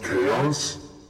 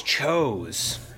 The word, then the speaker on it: chaos: Hold up. It's pronounced chaos? – Yeah. – I always thought it was chose. – I've just been saying chows.